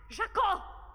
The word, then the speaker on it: Жако: Жако, ударение на ко!